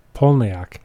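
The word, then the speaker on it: Palahniuk: It said that he pronounces it Polniak.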